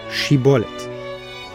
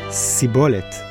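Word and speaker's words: shibboleth: They couldn't produce the shh sound. So anyone pronouncing shibolet as sibolet was killed on the spot.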